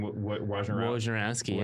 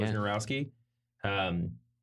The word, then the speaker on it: Wojnarowski: I'm going to mispronounce his name, but Adrian Wojnarowski. – Wojnarowski.